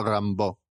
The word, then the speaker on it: Rimbaud: Es escritor wannabe, es adolescente torturado, o sea, es Rimbaud, aunque creo que se pronuncia Rimbaud, que lo estaba mirando estos días.